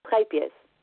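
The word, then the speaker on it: Scheepjes: how do you pronounce it? Sheep cheese? – Schepjes.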